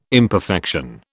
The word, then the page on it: imperfection: British English